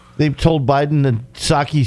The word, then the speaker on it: Psaki: Psaki. – So, you know, in Japan, instead of a margarita. – The P is silent.